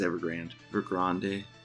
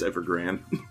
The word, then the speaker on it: Evergrande: What, how do you pronounce that? I think it's Evergrande. I fucking think of Mexicanos and they're Chinese. Pretty sure it's Evergrande.